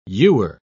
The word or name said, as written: ewer